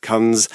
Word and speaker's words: Kunz: 1974, a sociologist called Phil Kuntz Not sure how to pronounce the name. – On the radio. Kunz. – Yes. – How would you say that? Anyway, K-U-N-Z. Philip Kunz, shall we say.